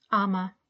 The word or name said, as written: Ama